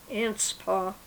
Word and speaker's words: Anspach: Anspaugh. – And how do you spell that? – A-N-S-P-A-C-H.